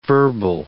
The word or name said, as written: verbal